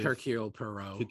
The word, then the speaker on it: Hercule Poirot: So it's kind of great to finally see him get his gold and to return in the Joaquin Poirot detective. – Hercule Poirot.